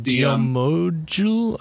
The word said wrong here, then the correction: Gomiju: Gomiju is how it's pronounced.